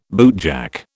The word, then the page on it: bootjack: British English